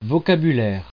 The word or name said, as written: vocabulaire